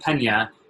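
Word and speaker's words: Peña: Specifically, my last name, Pena, without the NA phonetically turns into the word Pena, which is actually shame or embarrassment.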